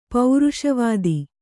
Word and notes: ಪೌರುಷವಾದಿ: pauruṣavādi

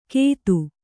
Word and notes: ಕೆತ್ತು: kannada

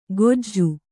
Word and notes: ಕೋಜು: kannada

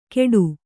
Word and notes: ಕೆಡು: keḍu

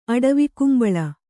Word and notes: ಅಡವಿಕುಂಬಳ: aḍavikumbaḷa

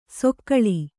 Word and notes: ಸೊಕ್ಕಳಿ: sokkaḷi

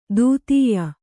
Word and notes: ದೂತ್ಯ: dūtya

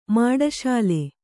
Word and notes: ಮಾಡಶಾಲೆ: Kannada

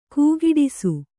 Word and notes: ಕೈಕೂಡಿಸು: kaikūḍisu

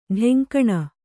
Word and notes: ಢೆಂಕಣ: kannada

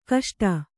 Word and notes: ಕಷ್ಟ: kaṣṭa

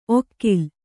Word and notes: ಒಕ್ಕಿಲ್: okkil

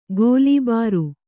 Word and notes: ಗೋಲಿಬಾರು: gōlibāru

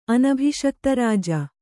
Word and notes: ಅನಭಿಷಕ್ತರಾಜ: anabhiṣiktarāja

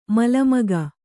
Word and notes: ಮಲಮಗ: mala maga

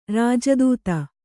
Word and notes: ರಾಜದೂತ: rāja dūta; kannada

